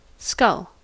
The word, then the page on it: skull: American English